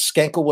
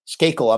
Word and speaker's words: Skakel: Dagger Love says, Skankle was, uh, Skakel, I'm not pronouncing that right.